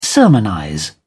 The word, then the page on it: sermonize: British English